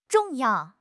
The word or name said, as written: zhong4 yao4